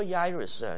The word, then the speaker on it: Jairus: You remember Jairus, you know, in Latin, J should be pronounced Y in Hebrew too. Jairus.